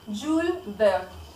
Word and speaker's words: Jules Verne: Para, sí, el dato curioso, su nombre se pronuncia, él nació en Francia, entonces se pronuncia Jules Verne.